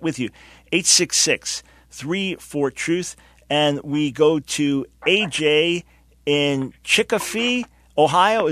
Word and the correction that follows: Chillicothe: Is that the pronunciation? – No, sir. It's Chillicothe.